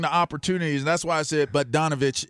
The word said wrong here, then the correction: Bogdanovich: is the – I don't even know if I'm saying it. Am I saying it right, guys? I feel like I'm saying it right. – Bogdanovich? – Yeah, there we go.